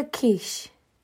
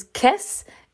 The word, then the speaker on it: Kiss: Now, um, I did talk about this briefly on TikTok, and I kept and I pronounced his name as Kiss instead of Kish because I'm an idiot who sometimes does not pronounce things correctly, and I was I was quickly corrected twice. good, good for you. Um, I was quickly corrected twice regarding my mispronunciation of of quiche, but it looks like K I S S in English.